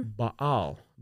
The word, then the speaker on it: Baal: And it's spelled B-A-A-L. And Siaga. – Mm-hmm. – So Baal. Yes, Baal.